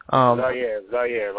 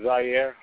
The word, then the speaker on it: Zaire: Zaire, like the country. – Okay. Go ahead and introduce yourself for us, Zaire. – My name is Zaire.